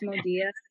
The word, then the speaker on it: Modia: So it's Modia.